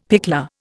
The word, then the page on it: pickler: Indian